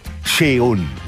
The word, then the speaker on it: Seun: Sheon Kuti ist der jüngste Sohn der Afrobeat-Legende Fela Kuti, schreibt sich S-E-U-N, und ich habe heute verzweifelt meinen WDR-Kumpanen aus frühester Zeit, Francis Gay, angeschrieben, der mir dann auch prompt zu Hilfe kam und sagte: Sheon, so spricht sich das aus.